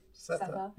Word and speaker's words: Sethe: Sethe. How, Okay. how did you say it? – Sethe. Yeah. Sethe.